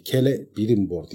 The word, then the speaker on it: Celebrimbor: Celebrimbor Sindarin okumasında öyle okunuyormuş diye okudum bir yer gazetelerden ki önemli bir adamın dediği bir şeydi. Kele Brimbor diye okunuyor demişti makalesinde. Ben o yüzden öyle diyorum ama Kele Brimbor da demenin bir sakıncası yok.